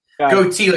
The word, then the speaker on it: Gootee: Goatee, like the beard that I won't grow.